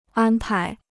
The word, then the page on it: 安排: ān pái